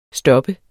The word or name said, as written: stop